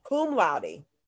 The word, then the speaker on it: cum laude: Cum laude.